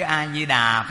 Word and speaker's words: A Di Đà: Còn năm ở đây là quý vị đang niệm Nam Mô A Di Đà Phật mà ha, thì chữ A Di Đà phát âm á, nguyên âm nó là Amita, chúng ta phát âm trại trại là A Di Đà, nó quen rồi.